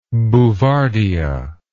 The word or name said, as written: bouvardia